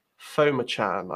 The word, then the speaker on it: Phommachanh: Foma Chan, I believe is how you pronounce it, but it doesn't read like that.